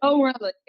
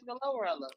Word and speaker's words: GloRilla: Gorilla? Or is it Glorilla? I don't know how you say the shit. – Glorilla. Yes, Glorilla.